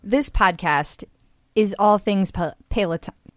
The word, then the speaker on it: Peloton: So, okay, we know, no need to write in, we know that it's pronounced Peloton.